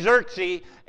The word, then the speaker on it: Xerxes: First of all, there's King Xerox. It's probably King Xerxes, but you know, it doesn't make any sense. I can't pronounce it. So King Xerxes, and he's also known in most places as Ahasuerus.